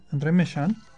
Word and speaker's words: André Méchain: En 1780 fue cuando publicó su segunda versión, donde llega hasta M68, y ahí es donde llega su asistente, André Méchain, que así se pronuncia, francés, que fue su ayudante, como él había sido el discípulo de Lyle.